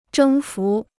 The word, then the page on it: 征服: zhēng fú